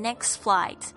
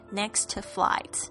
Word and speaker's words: next flight: 那这边的 the next flight 就是下一班的航班，那这边呢你会特别听到就是这个当 Mr. Wong 念很慢的时候 next flight，他会这样子说，但是呢当他变成正常语速的时候你就几乎听不到这个中间的这个 T，他是念 next flight 而不是念成 next flight。